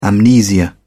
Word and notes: amnesia: British English

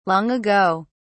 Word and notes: long ago: British English

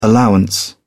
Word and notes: allowance: British English

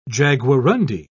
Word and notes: jaguarundi: British English

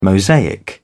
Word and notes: mosaic: British English

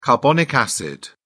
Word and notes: carbonic acid: British English

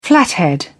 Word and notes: flathead: British English

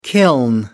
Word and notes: kiln: American English

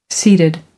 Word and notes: seated: American English

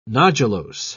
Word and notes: nodulose: British English

